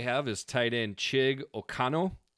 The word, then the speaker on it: Okonkwo: So Okonkwo. – Okonkwo. – Okay. – Yep. – So the second K is silent. – The second K is silent, I guess.